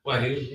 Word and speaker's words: Wagyu: Wagyu, yeah. – Wagyu. – Is there a that may be what I, that may be on my menu. – I think it's Wagyu.